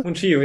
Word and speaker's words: Mungiu: Christian, wie hast du ihn ausgesprochen jetzt? Mungiu, oder? – Mungiu, ja.